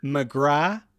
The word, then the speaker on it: McGrath: Katie McGrath, the T is silent. It's not Katie McGrath. She went to school across the road from me. Like, believe me when I say it's not Katie McGrath. It's Katie McGrath.